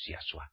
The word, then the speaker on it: Yahshua: Porque es un hombre verdadero y nos lo ha dejado escrito en el Brigada San Pato Renovado, es Yahshua. No es como ustedes mesiánicos lo pronuncian.